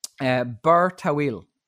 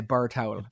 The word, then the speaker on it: Bir Tawil: there's a place called Bir Tawil. I think is how you pronounce okay. – it. That's the Arabic pronunciation. It means tall water well. Every single part of my Midlands head wants to say Bir Tawil. – Bir Tawil. – It really does. What does an awfully person put on a sunbed to reserve it? Bir Tawil. And it's somewhere between those two pronunciations.